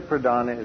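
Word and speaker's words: pradhāna: P-R-A-D-H-A-N-A with a long A, pradhana, which means the chief.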